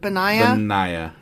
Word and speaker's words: Benaiah: Here, how would you say that name? – Where's that? – Benaiah? – Yeah.